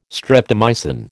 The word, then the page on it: streptomycin: American English